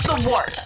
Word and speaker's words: The Wort: This is The Wort on Govs Radio. – It is The Wort, not wart, not fort, not short, The Wort.